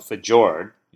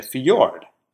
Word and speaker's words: fjord: Yeah, but you don't say we have to cross the fjord. You say fjord.